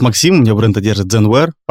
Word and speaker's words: Zenware: Вообще, многие не очень понимают, как это э, произносится, когда видят само название. На самом деле, это аллитерация сокращение, потому что первоначальное э, название бренда Zenware, два слова, mm-hmm. да? Zen, как э, дзен-буддийская традиция, переводится глубокое сосредоточение.